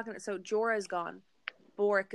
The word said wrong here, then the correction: Beric: Beric. – Beric, thank you.